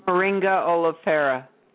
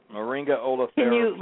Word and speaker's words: Moringa oleifera: Moringa oleifera. – Moringa oleifera plant.